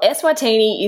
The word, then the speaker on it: Eswatini: Well, Eswatini is correct. I know that. Don't know about anything else. – Okay. How do you know that it's correct? – Because Wikipedia kindly wrote it phonetically.